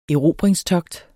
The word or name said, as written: erobringstogt